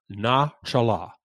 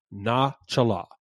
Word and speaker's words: nachalah: Now, that word gift, that phrase gift of in Hebrew, is the word Nachallah. Nachallah. It is spelled N-A-C-H-A-L-A-H. Nachallah. Nachallah